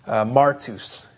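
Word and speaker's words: martus (μάρτυς): It's the Greek word that's pronounced martus, and so we get our word martyr directly from this word martus.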